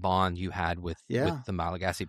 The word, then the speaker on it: Malagasy: And that's the Malagasy people. Is that how it's said? – Malagasy.